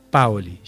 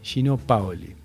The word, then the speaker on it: Paoli: Y así que el queridísimo periodista y escritor Néstor Tirri nos dice: perdón si te corrijo, pero es una cuestión de dónde va el acento. Si volvés a mencionar a Gino Paoli, creo, o al otro, que se llama Paolo, poné el acento en la A. Paoli, Gino Paoli.